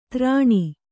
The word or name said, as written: ಧರಣಿ